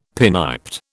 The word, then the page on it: pinniped: British English